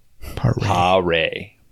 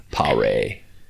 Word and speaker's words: Paré: Paré. – Paré. Like P-A-H-R-A-Y, Ray. Paré.